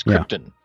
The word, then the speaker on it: Krypton: they pronounced the planet like the noble gas. – Krypton. – So it was not Krypton. It was Krypton.